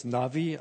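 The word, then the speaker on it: Navi (נביא): Der Prophet ist im Hebräischen der Navi, oder müsste es Navi aussprechen, es wird weich gesprochen.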